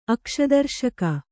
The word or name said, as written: ಅಕ್ಷದರ್ಶಕ